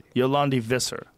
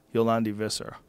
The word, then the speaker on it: Yolandi Visser: Yolandi Visser. That's it. Yolandi Visser.